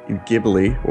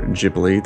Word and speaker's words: Ghibli: We're talking about Studio Ghibli, or Ghibli, depending on how you want to pronounce it.